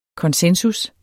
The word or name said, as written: konsensus